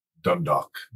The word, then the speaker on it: Dundalk: Dundalk. – Dundalk, Canada, outside of Toronto.